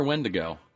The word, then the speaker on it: Wendigo: Is it Wendigo or Wendigo? I think it's Wendigo. – Wendigo. It's got a weird pronunciation.